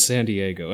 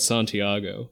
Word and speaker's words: Santiago: it's not San Diego, it's Santiago.